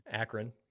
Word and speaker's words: Akron: That game against Akron, however you pronounce it, had me extremely nervous. – Akron.